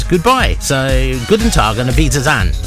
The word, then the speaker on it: guten Tag: He sent an email in, and he goes, "You sounded pretty good saying guten Tag." Guten Tag, yeah, which is, he says it's good morning, and I pronounce it correctly.